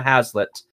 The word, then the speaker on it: Hazlett: at CM hazlett.